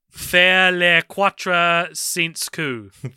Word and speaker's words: faire les quatre cents coups: Can I get your pronunciation of the French title again? – Faire les quatre cents coups.